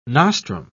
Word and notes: nostrum: British English

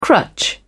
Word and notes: crutch: British English

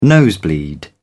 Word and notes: nosebleed: British English

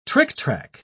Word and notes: tricktrack: British English

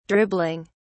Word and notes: dribbling: British English

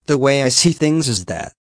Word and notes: the way I see things is that: American English

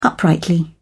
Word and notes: uprightly: British English

